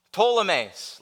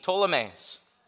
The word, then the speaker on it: Ptolemais: Ptolemais, like pterodactyl, silent P, Ptolemais.